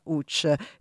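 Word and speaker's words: Łódź: Grazie mille per la canzone polacca, si pronuncia Sue la canzone, beh, come Lotz che si pronuncia Uch.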